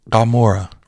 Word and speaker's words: Gomorrah: So I wanted to have the article just to draw from, but like the city Gomorrah in Hebrew, that G is not a hard G necessarily. So that letter is Gimel, which is a hard G. But this is spelled with a letter called an Ayin. So it's more like Gomorrah, right? It's like the back of the throat G. And this Ayin can also make an O sound. So it's not just G. It's not a hard G. It's in the back of the throat and often can make the O sound as well.